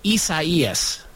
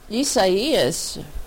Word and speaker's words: Isaias: Oh, which by the way, yeah, for those people who don't know, this hurricane it's pronounced Isaias. – Isaias. – Isaias. See, you can say it.